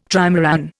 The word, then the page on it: trimaran: Indian